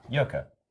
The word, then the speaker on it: Yurka: Probably like, how would you say that? – Yurka.